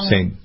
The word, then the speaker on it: Chateau St. Jean: St. John Winery.